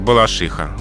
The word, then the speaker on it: Балашиха: Да, но все-таки словари собственных имен, в том числе словарь собственных имен русского языка Флоренции Леонидовны Агеенко, рекомендует только один вариант – «балашиха». «Балашиха» и «балашихинский район». Им такой вариант соответствует строго литературной норме русского языка.